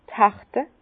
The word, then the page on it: d' Herte: Bas-Rhin; Herrlisheim